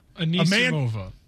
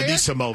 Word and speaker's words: Anisimova: Anisimova. – How do you say Anisimova.